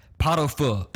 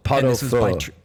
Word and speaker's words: Pot au Feu: Pot au Feu. – Pot au Feu.